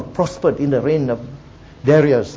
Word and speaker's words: Darius: So, but the point is that for the purpose of our study, we'll pronounce it as Darius, just as we pronounce it as Daniel.